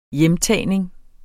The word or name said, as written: hjemtagning